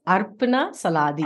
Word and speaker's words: Arpana Saladi: My name, we pronounce it in our dialect, is Arpana Saladi.